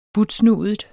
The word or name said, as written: butsnudet